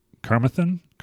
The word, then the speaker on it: Carmarthen: No, Philip Vaughan, a Welsh inventor and iron master who created the design for the ball bearing in Carmarthen. – How do you spell it? – C-A-R-M-A-R-T-H-E-N. – Carmarthen. – Okay. – I think Carmarthen.